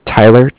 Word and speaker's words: Tyler: Okay, my name is Tyler, T-Y-L-E-R, and I'm with Bank of Cardiff. It's spelled C-A-R-D-I-F-F, as in Frank.